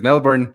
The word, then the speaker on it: Melbourne: La gente de Melbourne no le gusta que lo pronuncies Melbourne. Lo tienes que pronunciar Melbourne.